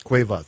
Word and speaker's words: Cueva: We had, before Bibi Tinga, we heard George Drexler out of Uruguay from his new CD, Bala in La Seva, or Suva. – Cueva, thank you.